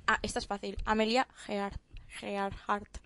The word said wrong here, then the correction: Earhart: perdón, por la e-, pronunciación. Emilia Earhart.